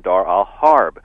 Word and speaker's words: Dar al-Harb: In Arabic, it's pronounced Dar al-Islam and Dar al-Harb.